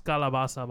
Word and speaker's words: calabaza: It's calabaza, by the way. Calabaza, that's how you say it.